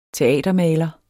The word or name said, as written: teatermaler